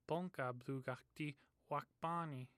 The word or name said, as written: bthuga